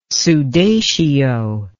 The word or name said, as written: sudatio